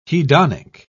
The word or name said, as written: hedonic